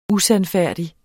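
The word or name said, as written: USANDFÆRDIG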